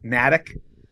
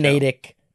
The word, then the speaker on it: Natick: I'm just glad that you pronounce it correctly, because a lot of people I've heard who aren't from there call it Natick when they're talking about Doug Flutie, because that's where Doug Flutie's from.